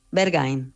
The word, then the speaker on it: Berghain: ¿Cómo lo llamas tú? Sí, bueno, Berghain.